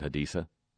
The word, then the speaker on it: Haditha: Haditha, and why can't we just, you know, be honest and pronounce it Haditha? Why do we have to do the lisp like the king of Iraq does?